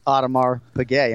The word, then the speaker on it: Audemars Piguet: And it's not Audemars Piguet.